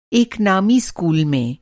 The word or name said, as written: नामी